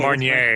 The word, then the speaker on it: Marnier: How do you say that? I can never say Marnier. – This Marnier. Marnier.